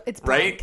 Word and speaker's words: Blanc: Speaking of Mel Blanc, why is it blank? It's spelled like Blanc to me. – No, it's not spelled like Blanc. You have that it's spelled like Matt LeBlanc. – Yeah, it's blank.